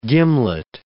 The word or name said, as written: gimlet